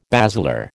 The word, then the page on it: basilar: American English